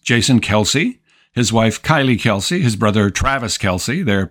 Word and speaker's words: Kelce: Our first film is Kelsey. That's spelled K-E-L-C-E.